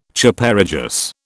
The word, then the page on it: Chaparejos: British English